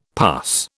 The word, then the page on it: pus: British English